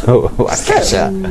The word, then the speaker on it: Waukesha: I think if you can pronounce something such that it sounds like Kesha, you should do that. – Yes. Waukesha.